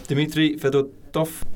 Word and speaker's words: Dmitry Fedotov: All right, Dmitry Fedotov.